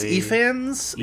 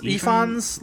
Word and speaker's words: Ifans: I think it's Efans. Efans. Like I think you I think you hit the first syllable hardest on it, but like yeah.